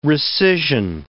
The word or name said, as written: rescission